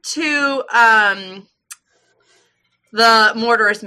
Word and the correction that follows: Marauders: map. Is that how you say it? I thought it was I say it wrong, I think. – I thought it was Marauder.